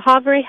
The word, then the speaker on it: Havre: And another amazing, it's the Havre House. Am I saying that right? – Yeah, the Havre House. It's on 919 Havre Avenue. – And that is H-A-V-R-E, correct? – Correct.